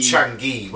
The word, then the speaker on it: Chang'e: Change. Maybe that's not how you say it's the word. It's probably Chang's. – It'll be Chang'e, won't it? – Yeah, probably. – Sorry, So when I've said change, replace it for Chang'e.